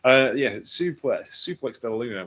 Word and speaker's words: Suplex della Luna: I kind of pronounced that word. I can pronounce suplex, but I was like, Circa della Luna. What? Suplex della Luna, which he rarely hits because it's so difficult to get on, which is highly inspired by Joshi wrestlers.